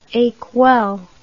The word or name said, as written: ecuelle